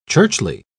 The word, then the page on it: churchly: British English